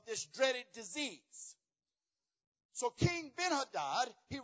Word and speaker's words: Ben-Hadad: King Ben-Hadad, as it's pronounced.